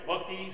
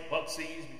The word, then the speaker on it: Buc-ee's: And now, some people pronounce it Buc-ee, some might say Buc-ee's because of the, it's B-U-C with an apostrophe and then E-E.